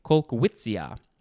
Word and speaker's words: Kolkwitzia: Colquitzia amabilis. And the first word is spelled K-O-L-K-W-I-T-Z-I-A, Colquitzia.